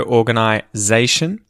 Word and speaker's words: organization: The emphasis in these words tends to be on the second last syllable, that zay part of zation. So, organization. And it's said like a Z instead of an S.